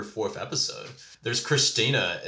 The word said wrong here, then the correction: Christiania: in Denmark that is operated as, like... – Christiania. – Christiania. Yeah. Sorry, whatever. But, yeah. I suck at pronouncing stuff.